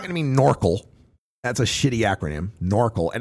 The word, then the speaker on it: NORCL: NORCL rhymes with snorkel.